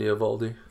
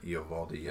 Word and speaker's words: Eovaldi: Nathan Eovaldi. – Eovaldi, yeah.